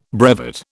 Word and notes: brevet: British English

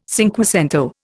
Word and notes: cinquecento: Indian

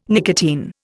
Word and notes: nicotine: Indian

